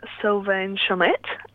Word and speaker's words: Sylvain Chomet: Sylvain Chomet.